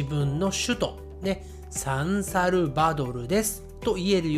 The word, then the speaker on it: サンサルバドル: サンサルバドルサンサルバドルはい、